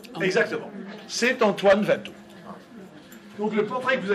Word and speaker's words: Watteau: Alors dans le Nord, on dit Antoine Watteau. Hein. Vous savez qu'il est de Valenciennes. Alors normalement, il vaut mieux le prononcer à la nordique, Antoine Watteau.